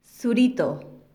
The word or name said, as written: zurito